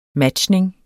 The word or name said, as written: matchning